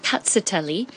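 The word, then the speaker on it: Rkatsiteli: How I remember that one, it's called Catsitelli. – Yeah. – And I have a cat that likes to watch television. So I imagine cat, see, telly.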